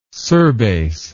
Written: surbase

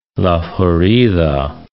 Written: La Florida

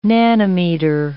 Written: nanometer

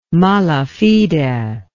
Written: mala fide

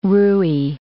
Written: rouille

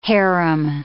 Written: harem

